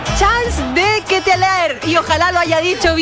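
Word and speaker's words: Charles De Ketelaere: Dije Charles de Ketelaer.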